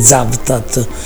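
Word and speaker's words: Cavtat: Della nave Zaftat, si scrive Kavtat ma si legge Zaftat, che poi è il nome di una bellissima cittadina che sta poco prima di Dubrovnik nel mare Adriatico.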